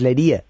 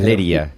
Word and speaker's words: Leria: Si ves Leria, pues dices Lería, pronuncia Lería, por eso hemos ido al Lería, ¿no? Claro, la Leria, ¿no? – Es Leria, ¿sí? – Leria. Leria, sí.